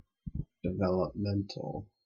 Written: developmental

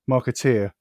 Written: marketer

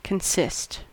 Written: consist

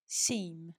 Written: seme

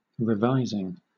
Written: revising

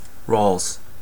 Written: rales

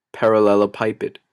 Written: parallelepiped

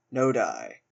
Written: nodi

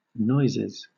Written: noises

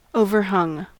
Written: overhung

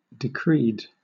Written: decreed

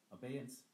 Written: abeyance

